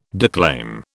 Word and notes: declaim: British English

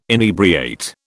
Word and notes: inebriate: British English

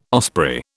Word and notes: osprey: British English

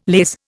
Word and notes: laze: Indian

